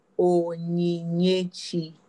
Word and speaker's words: Onyinyechi: Okay, so the full name is Onyinyechi.